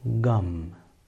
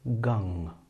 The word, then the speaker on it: gam: The first way is to pronounce the nasal sound as a simple M, gum. The second, more subtle way is to pronounce the nasal sound from the throat area, from the guttural part of the mouth, which will sound as gung, gung, gung. So either simple gum or guttural gung.